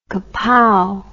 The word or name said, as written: kapow